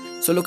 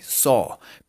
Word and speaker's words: saw: Ahora, el pasado de este verbo, la segunda columna sería so. ¿Sí Como una O abierta grande. So. Pero se escribe así. S, A, W. ¿Sí S, A, W. Listos. Y se pronuncia so.